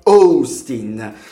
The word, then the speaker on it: Austin: Anzi, la pronuncia esatta di Austin è Austin.